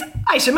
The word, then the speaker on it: Aicha: It's Aicha McKenzie. – Hello. – Did I say your name incorrect? I think that's... – No, you said it right. Aicha. – Aicha. – Like a silent A.